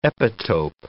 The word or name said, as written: epitope